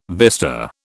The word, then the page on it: vista: British English